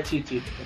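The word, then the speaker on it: Chi-Chi: Sobre o Yantia, vocês estavam comentando, ele machucou a Chichi. Chichi é que pronuncia? – É Chichi.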